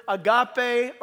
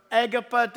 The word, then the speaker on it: agape: And that word love is that familiar Greek word, agape or agapa, depends on people pronouncing it. It doesn't matter. It's all spelled the same. Agape or A-G-A-P-E.